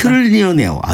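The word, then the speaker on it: trillionaire: 트릴리언에어. 아, 발음도 어려워. 밀리언에어가 아니라 트릴리언에어.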